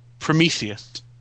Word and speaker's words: Prometheus: Prometheus.